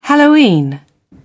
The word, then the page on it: halloween: British English